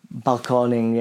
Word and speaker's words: balconing: Yeah, so balconing, yeah. So it's got an accent on the O, and it's a mixture of the Spanish balcony. I think, English.